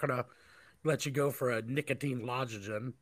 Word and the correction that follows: lozenges: Try it again, one more time. – Lozenge. How is it? What? No, not again. – Once more. – Lozenge. Is there a Z in it? – Yeah, this is Z. – Lozenge. How do you say it? I know what it is. If I typed it, I could spell check it. I get it. – Lozenges. – Lozenges? – Yes.